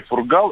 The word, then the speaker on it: Фургал: Просто многим режет уши, когда неправильно произносят фамилию Фургал. Сергей Фургал именно на первую букву ударения, на вторую – Фургал. А не Фургал, не Фургала и так далее.